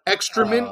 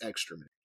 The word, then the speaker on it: excrement: You're saying it incorrectly, like he did. – That's what he was. It was his excrement pen. – Brian corrects him. – And he's like, no, fuck it, I'm still gonna say it wrong. – He just gives him a look like, uh, no, it's excrement.